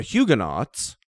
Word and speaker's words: Huguenot: Yeah, the French Reformed are known as the Huguenots, with the French pronunciation, the silent T at the end, the Huguenot.